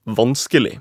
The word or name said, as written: vanskelig